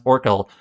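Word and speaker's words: Orchel: It looks like it's O-R-C-H-E-L, but it's pronounced Orkel.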